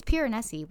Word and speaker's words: Piranesi: Piranesi, not Piranesi. Piranesi. – Oh, Piranesi. Yeah, I love that one. – Piranesi, you know what I'm talking about. Piranesi, but am I pronouncing it wrong? No, it's Piranesi.